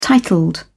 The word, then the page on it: titled: British English